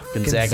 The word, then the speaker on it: Gonzaga: They're the Zags, not the Zogs. – Zaga. Gonzaga.